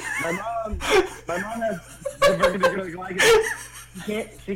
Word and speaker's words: diverticulitis: Shane has diverticulitis.